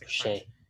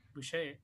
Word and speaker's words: Boucher: Like Boucher. – He's not good. Yeah. Boucher.